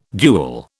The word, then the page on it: duel: British English